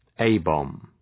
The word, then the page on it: A-bomb: ‘eı,bɒm